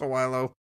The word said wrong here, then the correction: Sowilo: Uh, you're close, man. It's, excuse me. It's Sawilo. Sawilo. Sawilo yeah.